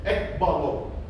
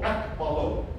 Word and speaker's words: ekballo: those two words are put together to form one Greek word that says ekbalo. E-K-B-A-L-L-O, ekbalo. That's the phonetic pronunciation, ekbalo.